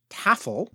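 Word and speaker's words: Tæfl: They also had other board games called collectively Tafl, that's spelled Ash. F-L. Ash is the sort of, it looks like an A and an E joined together. It has the A sound as in cat.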